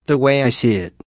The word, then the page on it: the way I see it: American English